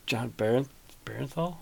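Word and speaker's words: Jon Bernthal: I heard that that John Barenthal? – Yeah.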